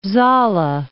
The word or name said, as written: Saale